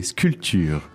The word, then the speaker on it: sculpture: Ouais, on prononce pas du tout le P, sculpture.